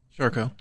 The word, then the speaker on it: Charcot: Charcot.